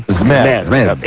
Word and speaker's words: Zmed: Adrian Zmed will be the first inductee, and you're right with the pronunciation. It's not Adrian Zmed. It's Adrian Zmed.